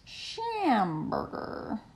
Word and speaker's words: Schamburger: Schamburger. It's fun to say. – Rhymes with hamburger. – Rhymes with hamburger. What? In fact, it says hamburger in the name. But spelled differently. – With a SC in front. – And with an ER in the middle.